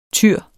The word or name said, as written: tyre